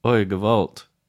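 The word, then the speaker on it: oy gevalt: Are you saying it right? Oi, gewalt. – Did right. I say it right? Yeah, you did good.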